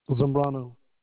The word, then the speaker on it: Zambrano: Zambrano.